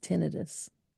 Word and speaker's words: tinnitus: And it's pronounced tinnitus.